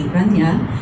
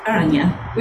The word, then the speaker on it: Aranya: So the Japanese pronunciation would be Aranya, and the Sanskrit is Aranya, which is unusual that it's so close.